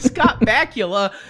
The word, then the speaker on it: Scott Bakula: It was Scott Bakula. – What was his character's name in Quantum Leap? – I don't even know. He's just always Scott Bakula to me, because his name rhymes with Dracula, so it's kind of cool.